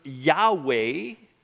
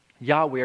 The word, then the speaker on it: Yahweh: And if we add the vowels, this is where we get the words Yahweh or the Latin pronunciation Jehovah. Yahweh or Jehovah.